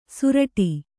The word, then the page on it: ಸುರಟಿ: suraṭi